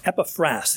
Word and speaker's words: Epaphras: Verse 12 talks about this man Epaphras. The emphasis ought to be on the last syllable, but if I mistake it, you'll understand who I'm talking about.